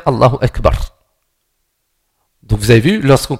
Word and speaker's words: Allahu Akbar: Il y en a qui disent Allahu Akbar dans le prononcer. Il n'y a pas de wa. Allahu Akbar. Donc Allahu Akbar.